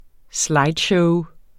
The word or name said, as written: slideshow